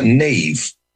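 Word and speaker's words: Niamh: I'm almost all right with S-I-O-B-H-A-N, Siobhan. – Right. – But Neve really freaks me out a little bit because Neve is spelled N-I-N-N-I-A-M-H. – Yeah, okay. – There should be a V in there somewhere just to give you a clue.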